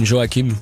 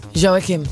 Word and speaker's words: Joachim: Joachim. – Yeah. He's Swedish anyway, so he's probably completely different. But he lives in France, so we call him Joachim. – Joachim.